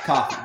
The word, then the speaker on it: Ko-fi: Oh, that makes a lot more sense. Yeah, coffee. But it... – Tell me that one. – No, it wasn't. But it's spelled Ko-Fi. It's Ko-Fi.